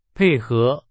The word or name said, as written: 配合